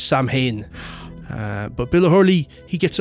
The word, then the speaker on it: Samhain: Bill O'Hurley, Irish man himself, he actually pronounces Samhain the correct way, Samhain.